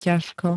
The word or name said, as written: ťažko